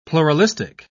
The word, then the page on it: pluralistic: British English